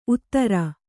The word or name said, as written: ಊದರೆ